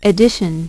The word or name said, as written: edition